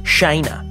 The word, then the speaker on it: Shayna: Close, but it's actually pronounced Shayna.